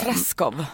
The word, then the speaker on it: Treschow: Eller så hade jag sagt så här, det är så här man uttalar det. Så bara, Treschow.